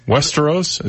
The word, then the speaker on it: Westeros: Westeros? Is that how you say it? – I believe so, yes.